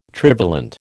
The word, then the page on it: trivalent: American English